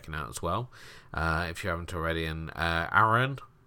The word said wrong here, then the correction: Arahan: I think, is that how you pronounce it? Arahan? Arahan.